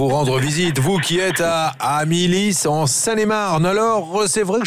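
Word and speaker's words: Amillis: On ne prononce pas le S, hein, j'ai bien compris, c'est ça, c'est Amilis. Ah, on le prononce, Amilis.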